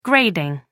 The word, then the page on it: grading: American English